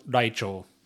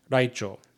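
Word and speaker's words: Raichō: Anyway, it's properly pronounced Raichou. So, Hiratsuka Raichou.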